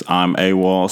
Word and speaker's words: I'm AWAL: For me, it's I'm AWAL. Spell it for you. I-M-A-W-A-L. That's I-M-A-W-A-L.